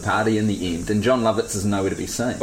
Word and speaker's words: Jon Lovitz: The second thing you get to see from John Lovitz is I like that you looked to me for approval.